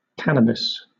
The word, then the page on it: cannabis: British English